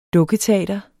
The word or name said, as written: dukketeater